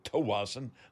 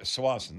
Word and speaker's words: Tsawwassen: So if I'm mixing it up a little bit, I like to do it because some say Tawassen, some say Sawassen, some people say Chawassen. So and, and I know people, the prop- most people can't spell it. – That's right. that is right, and uh, because not in the spell check, that's for sure. But uh, you know when you hear Swanson, that is the proper.